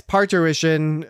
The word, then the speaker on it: parturition: Yes, parturition.